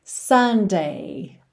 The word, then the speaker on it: Sunday: E finalmente, o domingo, que é o Sunday, Sunday. Que você pode pensar naquele sorvete maravilhoso, Sunday, que tem exatamente a mesma pronúncia, mas a escrita é diferente.